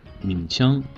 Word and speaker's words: 名声: 我们每个人都希望自己有一个好名声，对吗？ – 没错，这两个词，一个民生，一个名声，第二个字的发音是一样的，它们的区别呀就在第一个字上。民生的民是前鼻音，而名声的名是后鼻音。